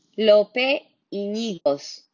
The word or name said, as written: Lope Ynigos